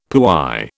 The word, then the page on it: puy: British English